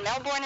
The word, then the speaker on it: Melbourne: Pronounce Melbourne like we pronounce it, incidentally.